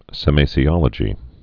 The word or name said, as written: semasiology